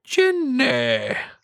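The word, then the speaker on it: Jinny: I still kind of think that maybe there's a chance that it's like a four-way and Ginny and Piper Niven get involved. – No, say her name right. – Ginny! – Thank you. – It's going to be J-I-N-N-E-H.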